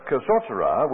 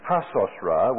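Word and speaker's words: hatzotzra: The kasosara, which actually is pronounced hasosra, which is the silver trumpet, this one here.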